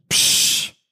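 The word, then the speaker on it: pssh: It's P-S-S-H, psh. That's what it sounds like.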